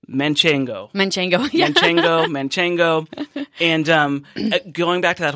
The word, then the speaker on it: Manchego: Manchego is a cheese from La Mancha, so it's Manchegan, like Don Quixote is Manchegan, and the full name of the cheese is Manchego, Manchego. – No N in the middle.